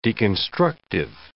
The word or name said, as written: deconstructive